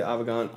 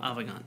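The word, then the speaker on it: Avegant: There's a new goggle coming out that's going to be a perfect sister ship to this HD Link, and I'm going to butcher the name, but I know you'll get it right. The Avagant. – Avagant, that's yeah. it. I always want to call it Avant, but it looks like a pair of Bose headphones.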